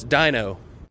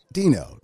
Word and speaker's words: Dino: Dino, not Dino. I mean, I get it. Dinosaur, but it's spelled D-I-N-O. – Right. – Yep. – But pronounced D-E-A-N-O.